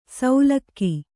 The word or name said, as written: ಸೌಲಕ್ಕಿ